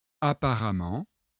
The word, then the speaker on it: apparemment: Notez que ça se prononce amant. Voyons les exemples. Apparent, adjectif. Apparemment, adverbe.